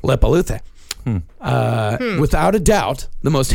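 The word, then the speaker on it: Leppalúði: These I know I'm actually pronouncing right. – Okay. – There is Grilla and Lepaluthe. – Huh. – Yeah. Grilla and Lepaluthe.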